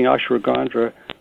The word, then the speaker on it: ashwagandha: And one of the things that she had mentioned was something called ashwagandha. I'm not really sure how to pronounce ashwagandha. – It. Ashwagandha. Okay.